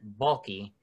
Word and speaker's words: Baalke: It's a short little thing, but today, Urban Meyer and Trent Baalke, I said it right, Trent Baalke.